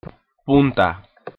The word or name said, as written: punta